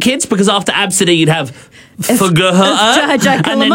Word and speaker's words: Abcde: Someone said, Claire, she said, my son went to a friend's birthday party last year and the kid's name was Absidy, which, Absidy sounds all right, but it's spelt A-B-C-D-E.